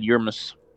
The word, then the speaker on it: Yermis: I'll mispronounce it again, but if you would like to correct me, now's a Wymerance? – I just said Yermis.